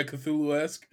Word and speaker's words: Cthulhu-esque: Oh, yeah, Cthulhu-esque.